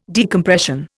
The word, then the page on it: decompression: Indian